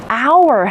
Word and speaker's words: hour: We call it hour hand. Pay attention to the silent H.